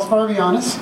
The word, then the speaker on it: Varvayanis: Charles Varvianis. It's spelled V-A-R-V-A-Y-A-N-I-S.